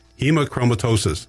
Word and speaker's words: Hemochromatosis: Hemochromatosis.